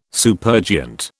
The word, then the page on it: supergiant: British English